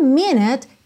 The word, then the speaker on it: minute: Como minute termina com E mudo, o último som então é o da letra T, que já junta no próximo T da palavra to.